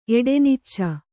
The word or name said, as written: ಎಡೆನಿಚ್ಚ